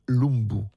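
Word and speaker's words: Lumbye: Ça s'écrit avec un Y à la fin, mais on le prononce Lumbu, appelé le Strauss du Nord.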